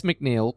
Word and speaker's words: MacNeille: Tress MacNeille, pronounced MacNeil.